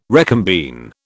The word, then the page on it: recombine: British English